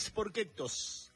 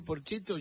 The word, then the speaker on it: Porchetto: Y Gino Reni decía, vos vado a cantar un tema de Raúl Sporquetos. ¿Quién? Decía Raúl Sporquetos. Claro, lo pronunciaba en italiano. Y me causaba mucha gracia. Entonces, cada vez que escucho a Raúl Porchetto, yo enseguida en mi mente, Raúl Sporquetos.